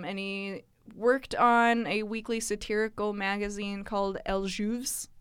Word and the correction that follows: El Jueves: I did that very French and completely incorrect. – Man. – Say that again. – El Jueves.